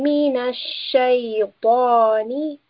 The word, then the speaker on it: الشيطان: Here we have the letter sheen, and above the sheen we have a shadda. So this means that we will pronounce the letter twice. Billahi minash shaytani.